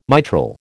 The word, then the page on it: mitral: American English